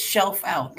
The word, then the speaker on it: Schelfhout: It's Scheifhout, like pull the shelf out.